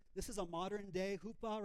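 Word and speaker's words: chuppah: It's not chupa, hoopa. And hoopa, the word, it's either spelled with a CH or with a H, because, and the hoopa, what it means is a covering.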